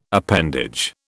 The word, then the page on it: appendage: British English